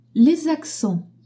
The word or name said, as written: accents